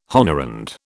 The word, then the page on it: honorand: British English